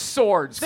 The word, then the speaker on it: swords: It's swords. Pronounced swords. – Swords. – Swords. – Wait, how's it spelled? – S-O-R-D-S. Duh.